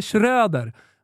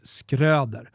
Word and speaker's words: Schröder: Eh, nu nämnde vi det i förbifarten, men Felicia Skröder, som eh, de har berättat för mig att hon heter, vilket är helt ofattbart eftersom hon stavas Schröder. Enligt alla språkregler i hela världen så blir det Schröder. Men, men hon har själv eh, uttalat det i en, eh, i en video som jag fick skickad till mig och det är Skröder.